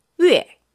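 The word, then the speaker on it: 위에 (月): 월, 월. 발음이 중요해요. 자, 네. 위. – 위. – 위에. – 위에. – 자, 입 모양은 우로 하시고요. – 우. – 그쵸. 발음은 위로. – 위에. – 그렇죠. – 위에.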